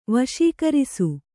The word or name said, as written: ವಶೀಕರಿಸು